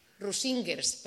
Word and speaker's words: Rusingers: Rusingers, perdón, con acento en la I.